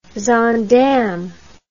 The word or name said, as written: zaandam